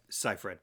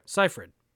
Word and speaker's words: Seyfried: 2009, Megan Fox, Amanda Seyfried? Is that how you say it? – Seyfried. – Seyfried. Okay.